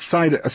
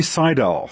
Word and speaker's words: Saidel: And let me just say it's Saidel before you say it wrong again. – It's, it's Saidel. Sorry, Saidel.